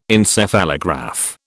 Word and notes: Encephalograph: British English

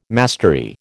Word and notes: mastery: American English

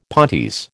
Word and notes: pontes: American English

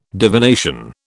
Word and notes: divination: British English

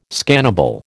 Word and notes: scannable: American English

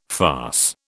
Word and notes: farce: British English

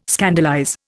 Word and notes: scandalize: Indian

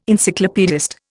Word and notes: Encyclopaedist: Indian

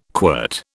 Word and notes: quirt: British English

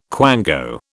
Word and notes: quango: British English

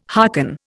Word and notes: hearken: Indian

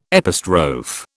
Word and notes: epistrophe: British English

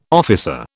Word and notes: officer: British English

